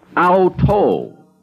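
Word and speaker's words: auto (αὐτό): The neuter for it in Greek is pronounced auto, from which we get the English word auto, or self.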